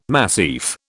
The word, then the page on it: massif: British English